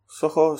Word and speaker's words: Zoho: Soho se escribe Z-O-H-O.